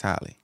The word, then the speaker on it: Halle: Haley Angel. Hallie, is it Hallie or Haley? I want to say that right. – I think it's Halle. – I think it's Halle, right? – Yeah.